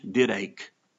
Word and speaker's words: didache: The Greek word teaching is didache, D-I-D-A-C-H-E. In the English, didache, but it's pronounced didache.